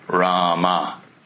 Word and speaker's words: Rama: Hanuman wrote on each rock, Rama, two syllables.